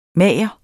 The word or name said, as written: MAGERENS